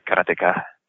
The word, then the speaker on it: karateka: I always said karateka, you know, but of course, the Japanese pronunciation wouldn't have a stress on any syllable, right? It would be something like karateka.